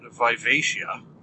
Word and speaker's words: Vivacia: So this uh, this series focuses around the live ship by the name of I'm going to pronounce it Vivacia. It is V I V A C I A.